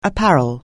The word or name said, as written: apparel